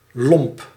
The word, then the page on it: grossier: France; /ɡʁo.sje/